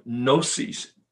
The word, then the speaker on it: gnosis: The agnostic comes from gnosis. The G is silent. G-N-O-S-I-S. Gnosis, which means knowledge.